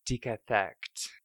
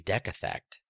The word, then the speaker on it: decathect: This week's word is, oh God, decathect. – Okay. – Decathect. – Decathect. How do you spell it? – DECATHECT. – It could be decathect. – No, it's D. – Is it a hard E or a long E sound there? – It's definitely a hard E.